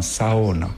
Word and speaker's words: sauna: En finnois, bien sûr, on appelle ça un sauna. – Donc c'est maintenant, on prononce toutes les lettres.